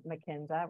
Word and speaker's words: Mackinzer: It's V-I-V-I-E-N-N-E. So it's Vivienne McKenzer, which is M-A-C-K-I-N-Z-E-R.